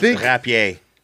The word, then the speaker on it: rapier: It's actually pronounced rapier because it's French.